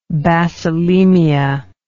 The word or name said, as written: bacillemia